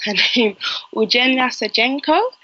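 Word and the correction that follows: Ulyana Sergeenko: Ulyana Sajenko, excuse me.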